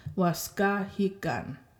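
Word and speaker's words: waskahikan: Okay, so third last syllable is the ga. Waskahigan. Right? So you just do that. Break it up and then go back and make sure that you're putting the emphasis on the third last syllable.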